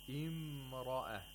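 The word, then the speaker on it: امرأة: فِي الْمَدِينَةِ امْرَأَةُ الْعَزِيزِ, imra'a, the ra has tafkhim, it has to be pronounced with thickness.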